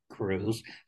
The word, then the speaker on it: Kruse: Well, you know me as Frank Kruse, spelled with a K, not a C.